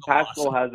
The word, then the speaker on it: Paschal: A lot of times we'll call him Paschal the Rascal, because that's how you pronounce his last name. It rhymes with Rascal.